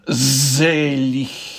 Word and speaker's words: Selig: Just watch the German pronunciation. Selig. Nice soft sound for the G there.